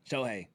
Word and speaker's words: Shohei: Yeah, Shohei.